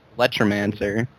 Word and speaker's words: Bulletromancer: I pronounce it Bulletromancer. – Yeah, Bulletromancer. – So one word.